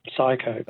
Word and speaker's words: Psycho: Psycho. – Oh, you found it just at the last minute. I was going to say it's got a silent P, but I thought, no, it's too much information.